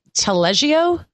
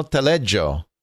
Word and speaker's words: Taleggio: Taleggio? T-A-L-E-G-G-I-O?